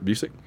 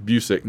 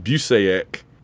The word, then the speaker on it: Busick: Was that the costume, and by the way, Kurt has told us all a million times, it's pronounced Busick. Not, I know people that pronounce it Busaic, yeah. – but that's not how Kurt pronounces it. He pronounces it Busick. Rhymes with music.